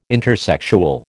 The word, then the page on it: intersexual: American English